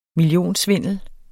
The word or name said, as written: millionsvindel